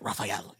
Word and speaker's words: Raphael: Yeah, you have to say it like that, Raphael.